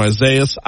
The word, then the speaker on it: Isaias: I'm pronouncing it Isaias because Google pronounced it Isaias. I know our news partners at WBRZ gave Isaias or something like that. Isaias is what Google says, so that's what I'm saying.